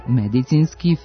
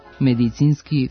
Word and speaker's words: medicinski: Ako medicinski, filološki i filozofski izgovarate na ovaj način, sa akcentom na prvom slogu, razgnevit ćete pre svega naše normativiste, budući da standardno jezička norma ovdje prihvata samo kratko uzlazni akcenat na drugom slogu. Dakle, medicinski, filološki, filozofski, biološki, pedagoški.